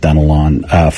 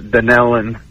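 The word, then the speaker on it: Dunnellon: I'm going to go with Dunelon, Florida, watching on YouTube. I hope I said the town right. I have not heard of that town before. – No, actually, it's pronounced Dunelon.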